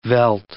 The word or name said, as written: veldt